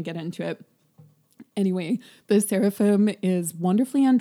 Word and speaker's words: Seraphim: But the more loved Cephalim. How do you pronounce their name? – Seraphim.